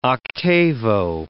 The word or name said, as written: octavo